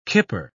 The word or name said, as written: kipper